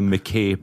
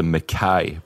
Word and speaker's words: Mackay: And you guys call it the McKay Bridge. Everyone listening, it's the Mackay Bridge is the correct pronunciation. It's very Scottish.